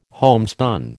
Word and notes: homespun: American English

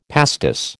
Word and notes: pastis: American English

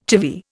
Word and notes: chivvy: Indian

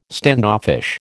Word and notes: standoffish: American English

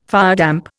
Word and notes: firedamp: Indian

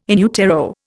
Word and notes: in utero: Indian

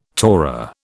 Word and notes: Torah: British English

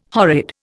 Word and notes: horrid: Indian

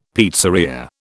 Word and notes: pizzeria: British English

